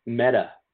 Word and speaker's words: metta: Can you compare that with metta meditation that you talk about? – Yes. Metta, which is spelled M-E-T-T-A, means loving kindness in Sanskrit.